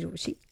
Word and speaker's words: Rochie: मेरा नाम है मिर्ची रोशी, काम है आपसे प्यार करना, नाम की स्पेलिंग है ROCHIE.